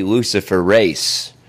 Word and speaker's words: Luciferase: There's an ingredient in the vax called Luciferis. It's mispronounced. It's actually Luciferase.